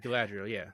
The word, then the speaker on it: Galadriel: Galadriel, yeah.